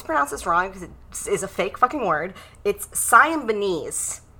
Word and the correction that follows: Symbionese: Liberation Army, which is just something they made up. – Let me see how it's spelled. – Siamese. S-Y-M... – Symbionese. – It's a silly made-up word, so the pronunciation is crazy. – Tell that to the Symbionese. – Well, they don't exist anymore because their name was foolish. So, Symbionese, we're going with that,